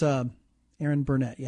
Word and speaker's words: Erin Burnett: Burnett is Erin Burnett. Yeah, that's how you pronounce her name.